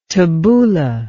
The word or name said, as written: tabouleh